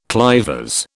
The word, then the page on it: clivers: British English